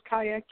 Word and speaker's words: Kaia: Uh, it is pronounced Kaia, K A I A. Yes, it is.